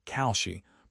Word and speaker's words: Kalshi: The Commodity Futures Trading Commission is currently overseeing prediction markets like Kalshi, pronounced K-A-L-Shi.